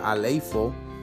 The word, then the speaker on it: aleipho: Las dos principales palabras griegas traducidas como unción en el Nuevo Testamento son aleipo, que se escribe A-L-E-I-P-H-O, aleipo, o quizás se puede pronunciar aleifo, que es usada para referirse a la unción de cualquier clase y la otra es crío, con su correspondiente sustantivo crisma.